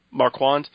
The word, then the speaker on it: Marquand: Marquand.